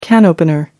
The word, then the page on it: can opener: American English